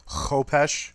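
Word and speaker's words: Khopesh: I'm reminding JR that Knight of Barsoom prefers the pronunciation Kopesh.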